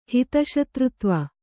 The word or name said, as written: ಹಿತಶತ್ರುತ್ವ